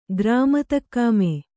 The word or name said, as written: ಗ್ರಾಮತಕ್ಕಾಮೆ